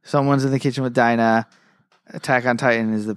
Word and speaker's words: Dina: It's pronounced Dina. – It's pronounced Dina. – I can promise you it's Dina.